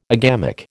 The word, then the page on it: agamic: American English